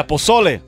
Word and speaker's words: pozole: But have you ever tried pozole? Is that how you pronounce it? – Yeah, pozole.